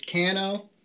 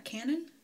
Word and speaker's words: Canoo: So there's a EV company called Cano. I think that's how you pronounce it. – Mm-hmm. – Spelled C-A-N-O-O. – Serious, it's not Canon? – No, only the one N.